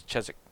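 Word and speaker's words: Chiswick: When we would, like, there's a town called Cheswick, and it's spelled Cheswick. And so as Americans, we all thought it was Cheswick, but it's Cheswick.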